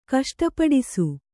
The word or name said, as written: ಕಷ್ಟಪಡಿಸು